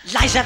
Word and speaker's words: Liza: C'est Liza avec Z, pas Lisa avec S, car Lisa avec S fait spaz.